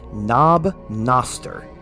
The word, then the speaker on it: Knob Noster: And then we will kick it off with the first one, Knob Noster. I hope I'm pronouncing that correctly. – Sounds right to me.